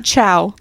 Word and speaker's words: Tso: But so Danny got the General Chow chicken. – TSO, pronounced Chow.